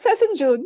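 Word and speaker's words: fesenjan: Like, I don't know how exactly to pronounce it, but it was F-E-S-E. And he's like, Fesin June.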